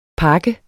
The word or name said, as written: pak